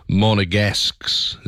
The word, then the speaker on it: Monegasque: I'm being told it's Monegasques. Is that how you say it? Monegasques. A resident of Monaco is a Monegasque.